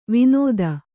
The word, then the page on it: ವಿನೋದ: vinōda